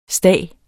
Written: stag

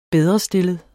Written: bedrestillet

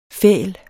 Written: fæl

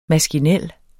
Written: maskinel

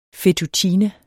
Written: fettuccine

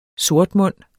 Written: sortmund

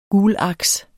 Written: gulaks

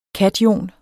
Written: kation

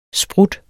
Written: sprut

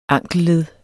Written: ankelled

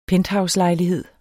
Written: PENTHOUSELEJLIGHEDEN

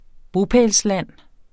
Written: bopælsland

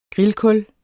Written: grillkul

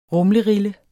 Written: rumlerille

